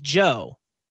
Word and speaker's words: Joe: Like my name is Joe, but if you're a British person, you're going to pronounce it Jew.